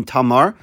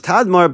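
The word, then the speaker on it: Tadmor: Pasuk Yudches continues, via Balas, the city of Balas, Yes Tadmar, there's a Kriyan Ksiv, it's written Tamar, but it's pronounced Tadmar, Bamidbar, Baaretz, in the desert near the populated area.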